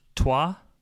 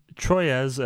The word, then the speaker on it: Troyes: Another one that we had played recently at Game Day, Tim, was Toi. I'll say that for you. – Toi. – Yep. – Are you sure? – Troyes, as some of us like to call it, but that is spelled Troyes.